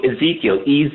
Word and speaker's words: Ezekiel: Maybe I'm pronouncing the name wrong. Ezekiel, Yeah, no, you're not pronouncing it wrong, but it's also a bread in the freezer of the fancy supermarkets.